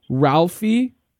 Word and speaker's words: Ralphi: Ralphi. – Spell it for me, please. – R-A-L-P-H-I. – Mm-hmm. Last name? – Rich. R-I-C-H.